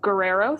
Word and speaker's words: Guerrero: Guerrero, thank you.